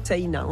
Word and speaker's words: Sena: Alors ici, cet espace gastronomique, Sena, ça s'écrit un peu comme la Seine, mais... – Voilà, avec un A à la fin, Sena, on prononce le I aussi. – Comme si on avait un tréma au-dessus.